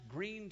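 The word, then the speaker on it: Gruene: It's actually spelled G-R, I think, O-E-N-E. Looks like growing, but it's actually, I understand, pronounced Green, Texas.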